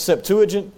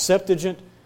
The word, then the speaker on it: Septuagint: Some people say it's Septuagint. I believe it's actually pronounced Septuagint.